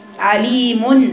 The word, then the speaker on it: عليم: عليم. من زمان والناس والبنات واحنا صغيرين كنا نكتب عليم دي بالايه؟ بالنون. اذا هي تلفظ بس الرسم موجود، لكن احنا بنكتبها نون في اخر في الكاف.